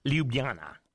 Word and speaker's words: Ljubljana: It's, how do you say it? It's the University of Ljubljana. Is that Slovenia in Russian? – Ljubljana. – Yeah, that's it.